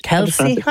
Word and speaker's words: Kelce: Is that pronounced it correctly? – Kelce? I would have said, go on. Yeah, great. Yeah.